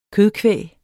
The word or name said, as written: kødkvæg